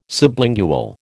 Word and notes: sublingual: American English

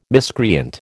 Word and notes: miscreant: American English

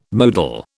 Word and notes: modal: British English

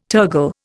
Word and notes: tergal: Indian

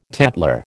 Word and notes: tatler: American English

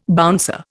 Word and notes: bouncer: Indian

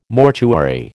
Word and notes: mortuary: American English

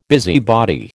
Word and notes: busybody: American English

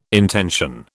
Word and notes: intension: British English

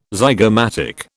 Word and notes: zygomatic: British English

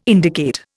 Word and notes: indicate: Indian

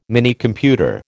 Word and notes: Minicomputer: American English

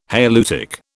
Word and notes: halieutic: British English

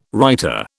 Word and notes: writer: British English

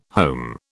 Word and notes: home: British English

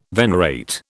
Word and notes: venerate: British English

